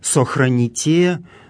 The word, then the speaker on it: сохраните: Здесь глаголы «сохраните», «сотворите», в которых мы слышим специфическое церковнославянское ударение, встречающееся именно в этих грамматических формах, второе лицо множественного числа настоящего времени, ударение на окончании.